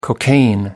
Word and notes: cocaine: American English

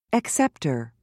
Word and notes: acceptor: American English

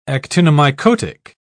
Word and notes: actinomycotic: British English

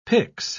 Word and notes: pix: British English

